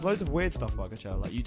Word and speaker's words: Coachella: Coachella, the festival, is that how you say it? Coachella? – Yeah, yeah, it is.